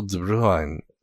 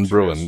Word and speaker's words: de Bruijn: It's Vil de Bruijn. And that's not perfect, but it's a whole lot better than Bruin, like I kept saying before.